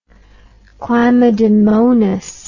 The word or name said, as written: chlamydomonas